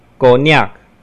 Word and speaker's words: cognac: 如果 c 出现在外来语的字尾，也是省略不发音，如 cognac。